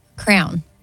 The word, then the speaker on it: crayon: Crayon. – Excuse me? – A crown. – I'll accept it. You said crown, but it's crayon.